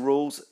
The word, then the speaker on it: Rawles: This is the Adventurer Club podcast series, and this is me, Jason Rawles, saying go out there, give it a go, have some fun, and plan something exciting for this weekend. Please come and find me, Jason Rawles. My surname is spelled R-A-W-L-E-S.